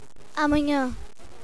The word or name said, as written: amanha